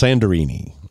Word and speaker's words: Santorini: Santorini, Greece. Actually, it's pronounced Sandorini.